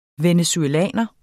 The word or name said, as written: venezuelaner